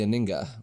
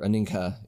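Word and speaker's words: Anhinga: So one bird in particular, the Aninga, or Aninka, if you like to pronounce the H, whichever, they are a really cool bird.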